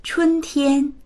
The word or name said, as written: chun1tian1